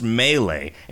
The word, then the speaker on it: Melee: Burbridge writes, hey, nerds at Video Games Hot Dog, it's pronounced Melee. It's not a guy named Lee introducing himself. It's me, Lee.